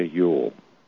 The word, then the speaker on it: Ewell: One, have you ever heard of Dana Yule? – Name doesn't ring a bell. – Right, it's spelled not Yule like the holiday. It's spelled E-W-E-L-L.